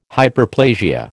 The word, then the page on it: Hyperplasia: American English